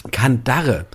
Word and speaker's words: Kandare: Also ich würde es aussprechen Kandare, aber richtig geschrieben ist es Kandare mit nur einem A. – Ja. Und, was hast du gesagt? – Kandate. – Ach so, du hast ein T am Ende eingeführt.